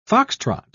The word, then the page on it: fox-trot: British English